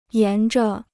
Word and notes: 沿着: yán zhe

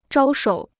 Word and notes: 招手: zhāo shǒu

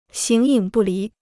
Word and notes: 形影不离: xíng yǐng bù lí

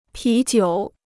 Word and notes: 啤酒: pí jiǔ

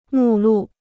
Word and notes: 目录: mù lù

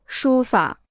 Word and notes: 书法: shū fǎ

